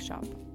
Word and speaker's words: scop: I act like a shop, which is an old English word pronounced kind of like shop if you're British, and it's spelled S-C-O-P.